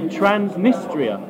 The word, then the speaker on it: Transnistria: Yeah, so it's called Transnistria. – Yeah. – Is that a country? – No. So they... – I want to listen to Transnistria.